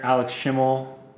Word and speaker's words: Alex Schimmel: So I'm with my good brother here, Alex Schimmel.